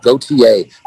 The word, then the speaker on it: Gauthier: Gauthier, it rhymes. My dog didn't. – I love that. – It's like Sean Paul Gauthier. – I love that. Gauthier, the goat herself, Renee, the goat.